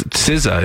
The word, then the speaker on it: SZA: That's SZA is the correct pronunciation.